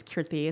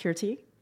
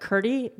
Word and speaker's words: Kirti: And my first name is Kirti. It's a soft T, but I'll accept Kirti. But Kirti, no. No.